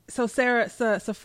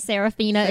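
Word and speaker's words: Safaree: Yeah, and I guess, so Safari. – Safari. I was like, if y'all call this man Serafina again, I was gonna fall out. Safari. Safari is actually how it's spelled, but.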